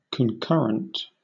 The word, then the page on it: concurrent: British English